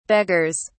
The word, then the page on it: beggars: British English